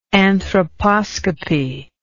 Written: anthroposcopy

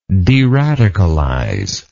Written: deradicalization

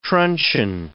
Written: truncheon

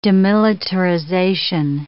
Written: demilitarization

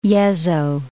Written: Yezo

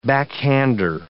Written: backhander

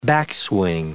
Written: backswing

